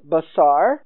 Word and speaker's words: basar: But we have an equivalent Hebrew word which is pronounced the same way. It's the Strong's number 1319. So thus it also is pronounced basar.